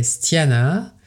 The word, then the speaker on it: styana: Alors le terme sanscrit est stiana et ça s'écrit S-T-Y-A-N-A, et ça vient de la racine stia qui veut dire se raidir, durcir, devenir ferme.